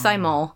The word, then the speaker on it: thymol: And it is pronounced thymol and thyme. Thyme's obviously an herb.